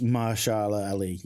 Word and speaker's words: Mahershala Ali: Is it Mahershala Ali? – It's Mahershala Ali, yeah.